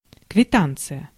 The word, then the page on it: receipt: American English; IPA: [rɪ.ˈsit]